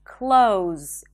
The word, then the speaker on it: clothes: And instead of saying clothes with a TH and a Z, you can just say close, as in close the door. Months, close.